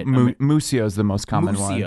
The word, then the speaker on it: Muccio: It's pronounced Muccio. – Muccio. – Like Mucho Grande? – Josh, mucho.